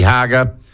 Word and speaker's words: Hager: Dirty Work, the latest book by, and I'll use inverted commas, investigative journalist Nikki Hager, rhymes with lager, and the subheading of the book, Dirty Work, the Taxpayers Union and New Zealand's Hard Right.